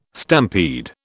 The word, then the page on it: stampede: British English